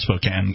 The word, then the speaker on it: Spokane: Even though it's pronounced Spokane, if you're from there.